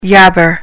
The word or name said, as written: yabber